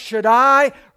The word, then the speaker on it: Shaddai: It's pronounced Shaddai, pointing us to the all-powerful God.